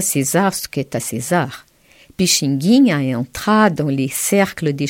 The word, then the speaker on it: Pixinguinha: P-I-X-I-N-G-U-I-N-H-A. – Merci Marie. Je répète la prononciation correcte. Pixinguinha et non Pixinguinha.